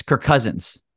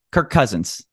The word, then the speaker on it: Kirk Cousins: It's Kirk Cousins. Kirk Cousins. You end his name and start his first name and start his last name all in the same syllable there.